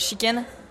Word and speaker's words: Sheken: Et euh, mon personnage s'appelle Chicken. Donc, comme un, poulet. mais ça s'écrit S-H-E-K-E-N, hmm. comme avec le pronom chi.